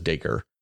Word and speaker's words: Dacre: And I looked it up, and that is the correct pronunciation, by the way. Just in case anybody was wondering, it is Dacre, because it's Acre with a D in the front of it.